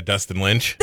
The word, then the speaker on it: Dustin Lynch: His first name rhymes with Justin and last name rhymes with Finch. – Yeah, Dustin Lynch.